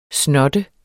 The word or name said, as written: snotte